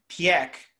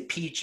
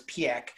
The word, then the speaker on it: Piëch: However, when Ferdinand Piëch, and that's how you pronounce it, a lot of people say Peach and Pike, and a lot of it's Piëch, was still alive, he was very much invested in keeping Porsche tied to Bugatti and all this.